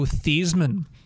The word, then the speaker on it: Theismann: Actually pronounced Joe Theismann. – Oh, yeah. – Reason is, when he got to Notre Dame, they said, we want you to change the pronunciation of your name because the new pronunciation would rhyme with Heisman, would help your Heisman Trophy campaign.